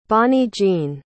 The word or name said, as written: Bonny-Jean